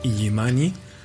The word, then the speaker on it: Illimani: Bueno, para los que no conozcan Iyimani, se escribe con dos L's, ¿no? I-L-L-I, Mani.